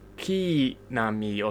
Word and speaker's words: Shikinami: It's Shikinami or something. It's like, yeah, the E sound comes after the K sound.